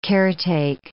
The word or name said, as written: caretake